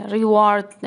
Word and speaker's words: reward: به کلمه reward اینجا دقت کنید. اینجا ایه reward نوشته ولی reward تلفظ میشه. یا کلمه مثل war W-A-R به معنی جنگ. War رو به O تلفظ میشه. War reward.